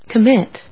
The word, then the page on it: commit: com・mit; kəmít